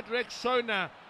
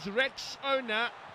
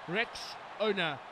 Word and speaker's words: Rexona: A friend called it Rexona, but guys, it is Rexona, Rexona. This